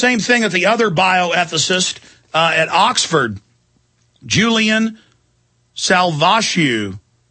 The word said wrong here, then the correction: Savulescu: am I pronouncing that right? Yeah, Julian Savulescu.